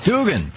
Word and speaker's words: Duggan: Am I pronouncing that correctly? – It's Duggan. – Duggan, thank you very much. Lisa Duggan is Associate Professor of American Studies and History at New York University.